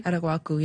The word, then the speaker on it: Araguaco: Araguaco, yeah. It's okay. Like guac.